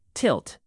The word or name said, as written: tilt